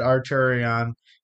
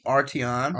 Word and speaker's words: Arteon: I said, okay, before recording, I said Arturion, and then I fixed it. – Yes, of course. – So the Arturion. – The Arteon. – Arteon. Arteon. I'm saying Arteon.